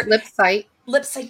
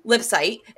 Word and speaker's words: Lipsyte: Sam Lipson is a multi-published author. – Lipsight. – God damn it. You're going to say his name in the closeout. Sam Lipsight is a multi-published author and screenwriter.